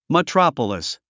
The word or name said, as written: metropolis